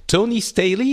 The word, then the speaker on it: Tony Staley: And so today I'm welcoming to the show Tony Staley.